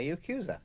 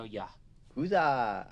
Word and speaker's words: Yakuza: The Yakuza. – Yakuza. – Yakuza. – Yakuza. You pronounce mm-hmm. the A more.